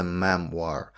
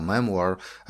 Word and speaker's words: memoir: Based on the memoir.